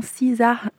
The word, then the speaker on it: SZA: On prononce son prénom SZA,